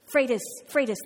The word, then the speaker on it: Freitas: Pete Freitas, did I pronounce that correctly? Freitas, thank you, Freitas.